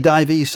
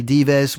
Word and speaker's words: Dives: And you're right, there is some debate and discussion about the pronunciation. Some say Dives, some say Dives, whichever. Still a fantastic piece of music.